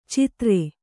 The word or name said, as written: ಚಿತ್ರೆ